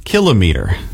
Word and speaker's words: kilometer: Second syllable stress, kilometer. The style is very common in everyday Canadian speech for some reason, influenced by measuring devices like thermometer and speedometer.